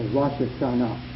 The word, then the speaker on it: Rosh Hashanah: Most of us Gentiles have gotten into a habit of saying Rosh Hashanah. Well, it's more properly said in Hebrew with the emphasis on the last syllable, the Rosh Hashanah.